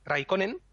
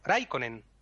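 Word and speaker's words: Räikkönen: Y el finés es justo lo contrario todas las palabras se acentúan en la primera. Por eso no se dice Raikkonen, por ejemplo, sino Raikkonen.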